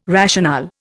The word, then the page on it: rationale: Indian